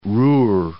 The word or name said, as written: Ruhr